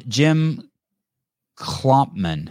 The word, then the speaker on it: Jim Klopman: Jim Klopman.